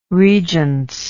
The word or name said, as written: regence